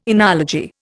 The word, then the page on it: enology: Indian